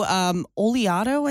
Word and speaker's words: Oleato: It's the new oleato, I think is how you say it. Oleato.